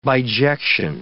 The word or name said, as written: bijection